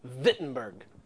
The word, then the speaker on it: Wittenberg: And yet he is sent to the town of Wittenberg, or you would say actually pronouncing the W, Wittenberg, pronouncing the W as a V.